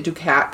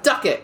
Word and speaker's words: Ducat: I was pronouncing it Ducat because it's D-U-C-A-T, and then I listened to a video by a person actually from Scotland, and they were like, Duckett, and I was like, oh, I had no idea. I pronounced that name wrong throughout history then.